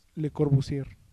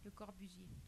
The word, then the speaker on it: Le Corbusier: Ah, esa una de Le Corbusier, o como sea que se pronuncia acá los franceses, que no me digan. Le Corbusier o Le Corbusier. – Le Corbusier. – ¿Ese one? – Le Corbusier. – Otra vez.